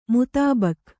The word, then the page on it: ಮುತಾಬಕ್: kannada